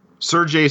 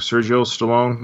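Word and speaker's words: Seargeoh: Sergei Stallone.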